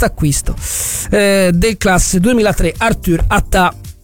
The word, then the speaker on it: Atta: Ricordiamo che si dice Attà e non Atta. Atta era Yattaman, il personaggio dei fumetti giapponesi. Questo è Attà, con l'accento sulla, anche se non si scrive con l'accento, ma si pronuncia Attà.